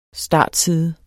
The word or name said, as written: startside